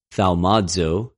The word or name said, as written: θαυμαζω